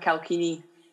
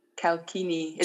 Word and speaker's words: Calchini: My whole life, it's like been pronounced strong. So my name is Catriona Calchini. – Right. I apologise. Catriona Calchini. – Calchini.